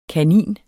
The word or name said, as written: kanin